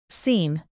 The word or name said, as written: scene